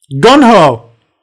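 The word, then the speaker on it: GungHo: Final story is, am I saying this right? Gung-ho? – Gun-ho.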